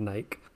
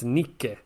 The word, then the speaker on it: Nike: And like British people said Nike and American people said Nike. And he was like, You're all idiots. It's Nike.